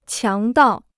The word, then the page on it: 强盗: qiáng dào